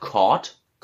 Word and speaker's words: caught: C-A-U-G-H-T wird Korth.